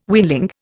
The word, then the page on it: weanling: Indian